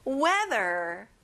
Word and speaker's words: weather: 날씨, weather. 자, th, the 발음을 정확하게 해보세요. weather, good job.